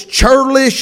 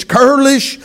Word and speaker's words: churlish: And she was a woman of good understanding and of a beautiful countenance, but the man was, I don't know if the H is silent, but if it is not, it's churlish. If it is, it's curlish.